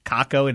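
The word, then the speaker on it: Kakko: Rangers fans let me know. No, you're mispronouncing it. It's Caco. – Wow. – Said, well, even better. – Caco it is.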